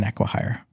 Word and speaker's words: acquihire: an aquahire An acquire. An acquihire. Is that how you say it? Yeah.